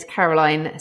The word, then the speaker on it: Caroline: And just for the people listening to this, Kavanagh is spelled C-A-V-A-N-A-G-H. All right? – It is indeed, yeah. And it's Caroline, C-A-R-O-L-I-N-E, because that can be spelt in lots of different ways as well.